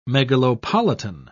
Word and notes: megalopolitan: British English

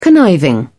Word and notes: conniving: British English

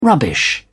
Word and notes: rubbish: British English

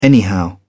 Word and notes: anyhow: British English

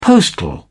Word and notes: postal: British English

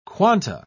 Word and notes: quanta: British English

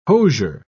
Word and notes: hosier: British English

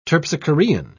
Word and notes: terpsichorean: British English

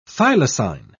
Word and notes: thylacine: British English

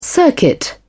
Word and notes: circuit: British English

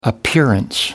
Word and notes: appearance: American English

